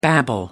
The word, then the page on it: babble: American English